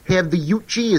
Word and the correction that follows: Yautja: Is that how you pronounce the Predator's race? – Yautja, I think they're pronounced.